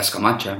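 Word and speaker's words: Scamacca: Samaka naar Peck, Of hoe spreek je het echt goed uit? Eh, ja, Scamacha.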